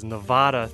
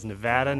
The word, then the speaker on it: Nevada: I would also really like to apologize to the great people of the state of Nevada, as I butchered the pronunciation of this beautiful state as Nevada throughout the podcast. I've learned my lesson. It is Nevada, Nevada, Nevada, and I will be back to Nevada as soon as possible.